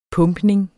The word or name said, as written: pumpning